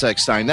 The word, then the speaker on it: Eckstein: I mean, what's the definitive pronunciation of the last name? Is it Eckstein or Eckstein? – It's Eckstein. – Of course it's Eckstein. That's what I've been saying all night.